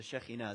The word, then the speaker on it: Shekhinah: And same in Judaism, you have the Shekhinah, or the Shekinah. The Shekhinah is how you say it.